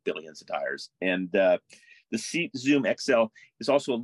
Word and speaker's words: CEAT: And Seat is spelled C-E-A-T, not S-E-A-T.